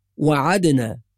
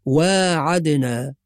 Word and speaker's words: واعدنا: أيضا في الآية التي تليها في قوله سبحانه وتعالى وإذ واعدنا موسى 40 ليلة، بعض الناس ينطقها وعدنا بدون ألف ولا ينتبه إلى الألف الصغيرة المرسومة بعد الواو، فالصواب واعدنا، وإن